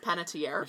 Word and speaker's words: Panettiere: Panettiere.